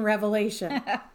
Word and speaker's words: Revelation: Okay, Chris, let's talk about why we titled the episode, There's No S in Revelation. Contrary to how many people pronounce the title of the book, and I admit I've been guilty of it unintentionally.